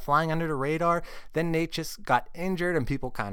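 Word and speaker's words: Nečas: For our top forward streamer pick of the week, it's Martin Natchez of the Carolina Hurricanes. I'm glad I looked up how to pronounce his name, because that is vastly different from Martin Neckes.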